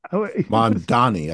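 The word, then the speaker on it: Mamdani: You mentioned the Mondavi Is it Mondavi? – Mondani, I think it is. – Mondani, thank you.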